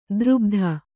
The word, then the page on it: ದುರ್ಬುಧ: kannada